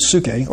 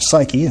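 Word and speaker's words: psyche (ψυχή): The word soul, there's a Greek word psuche, or psyche, as we pronounce it.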